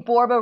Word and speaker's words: Borba: My last name, Borba, rhymes with Zorba.